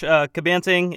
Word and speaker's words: Cabanting: Yeah, pretty much. Cabanting, it's a Hawaiian-Filipino name, if anyone has ever heard the name.